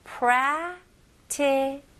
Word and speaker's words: practical: 여기에서는 이제 prac라고 할 때 첫 번째 그 C 발음을 그렇게 강하게 안 하셔도 됩니다. 바로 살짝만 큰 소리 내면서 T로 넘어가시면 훨씬 더 자연스러워요. 그래서 practical 보다는 practical. practical. 오케이? 강세도 앞부분에 들어갑니다. 해볼게요.